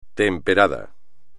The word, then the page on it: temperado: espagnol